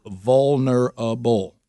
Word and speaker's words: vulnerable: And she goes, you got to slow it down. It's more syllables than you're making it. You roll it. It's vulnerable.